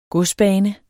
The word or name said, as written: godsbane